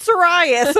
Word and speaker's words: Sirius: So I'm in serious black. I said Sirius.